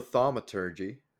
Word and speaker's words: thaumaturgy: And then at least the biggest one is that you know thaumaturgy.